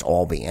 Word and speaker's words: Albee: And yes, it's pronounced Albee. And how do I know? Because I read his correction of a New York critic's pronunciation of his name. It is not Albee. It is Albee.